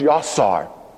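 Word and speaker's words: yasar (יצר): The Hebrew word that is translated formed is pronounced yasar.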